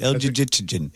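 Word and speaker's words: Elgygytgyn: How would you say this? – Yeah, I think you had it right. El Jijitsin.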